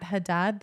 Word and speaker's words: Haddad: At Kat Haddad, that's H-A-D-D-A-D, because people always get my surname wrong.